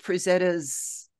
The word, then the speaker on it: Frazetta: And one of the other things that I wanted to say for those that picture Frank Frazetta's, did I say that right, Frazetta? I was confused. – No, that seems right.